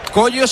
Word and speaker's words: Kojo: Es Coyo, no Pollo, ¿no? – Coyo se dice, pero se escribe Cojo. K-O-J-O.